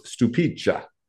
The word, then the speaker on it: Stupice: It's spelled Stupice, S-T-U-P-I-C-E, and pronounced Stupicha. So Stupice or Stupicha.